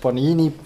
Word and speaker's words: Panini: Es ist korrekt. Panini. – Ja, ich bin ein Digital Native. Jeweils also. einem N. Was ein beliebter Fehler ist, irgendwo zwei, ein Doppel-N versuchen reinzuschmuggeln. Panini mit jeweils einem N an den jeweiligen Ort.